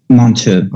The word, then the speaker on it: Monteux: Is it Montoya, Montreux? I think I mispronounced it earlier. – Mon- Montu. – Okay, Montu. Okay, I, I, did pronounce it wrong.